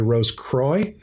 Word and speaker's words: Rose Croix: Uh, in in North America, m- many of us say Rose Croix. Uh, just so s- some of the listeners out there may be unfamiliar with Rose Croix, right, right. that pronunciation, but that is the French pronunciation.